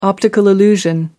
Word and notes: optical illusion: American English

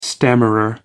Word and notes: stammerer: American English

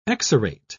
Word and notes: exarate: British English

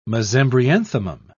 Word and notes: mesembryanthemum: British English

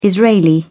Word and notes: israeli: British English

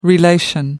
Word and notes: relation: American English

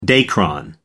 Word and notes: dacron: American English

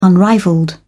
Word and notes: unrivalled: British English

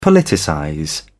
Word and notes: politicize: British English

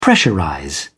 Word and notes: pressurize: British English

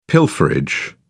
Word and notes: pilferage: British English

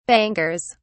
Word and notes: bangers: British English

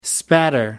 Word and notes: spatter: American English

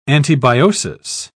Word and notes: antibiosis: British English

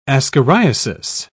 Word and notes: ascariasis: British English

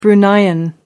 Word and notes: bruneian: American English

